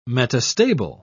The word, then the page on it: metastable: British English